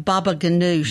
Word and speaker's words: baba ganoush: That's I saw that several people have baba ganoush. – Baba ganoush. – Am I saying that correct? – That's, right. – That's how I say it. – That's how you say it.